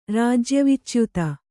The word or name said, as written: ರಾಜ್ಯವಿಚ್ಯುತ